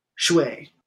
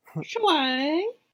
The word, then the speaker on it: Hsieh: They had a uh, Sue, and it's spelled H S I E H, which apparently is pronounced Shui. – Shui.